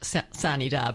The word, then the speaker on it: sine die: That's a Latin phrase, which is no doubt not pronounced sine die, but that's the way it's pronounced at the Capitol. This is Georgia. We'll pronounce it any way we want to.